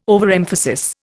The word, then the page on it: overemphasis: Indian